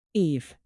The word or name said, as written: eve